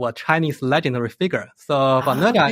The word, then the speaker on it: Nezha: Is it Nezha? – Nezha, yeah.